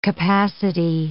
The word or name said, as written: capacity